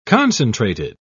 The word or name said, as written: concentrated